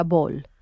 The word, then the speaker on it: Boll: Oye, confirmo que Boll se pronuncia Boll, ¿vale? – Boll.